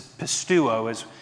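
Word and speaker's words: pistuo (πιστεύω): It's a Greek word that's pistuo is how you pronounce it.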